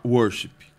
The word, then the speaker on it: worship: A Raquel corrigiu a gente, falou que tem que falar worship. – Ah, que a ênfase está na primeira sílaba.